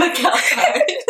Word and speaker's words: McAlpine: Did I say it right? McAlpine. – McAlpine. Lizzie McAlpine. Sorry.